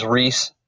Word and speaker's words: Ryse: Yeah, because my name's Ryse, R mm-hmm.